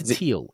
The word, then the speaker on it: Thiel: So yeah, 0 to 1 by Peter Thiel. Is that what you're saying? – Yeah, that's how you say his name.